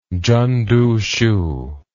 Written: chen duxiu

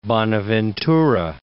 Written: Bonaventura

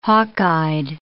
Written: hawkeyed

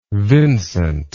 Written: Vincent

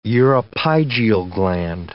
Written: uropygial gland

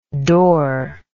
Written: dor

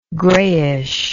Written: greyish

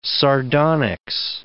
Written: sardonyx

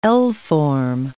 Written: L-form

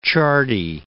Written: Ciardi

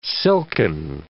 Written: silken

